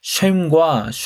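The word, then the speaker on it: 쉼 (שֵׁם): 이름의 히브리어 발음은 쉼입니다. 기름의 히브리어 발음은 쉐멘입니다. 쉼과 쉐멘은 모음과 자음 하나씩만 추가된 것인데요.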